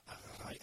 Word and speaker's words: Araraï: Donc voilà, en tout cas, en plein dans le livre, premier album qui s'appelait Araraï, si mes souvenirs sont bons, et je ne sais jamais trop le prononcer, mais. – C'est ça, Araraï, Araraï, comme tu veux, quoi.